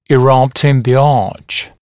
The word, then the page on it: erupt in the arch: ɪ’rʌpt ɪn ðə ɑːʧ; и’рапт ин зэ а:ч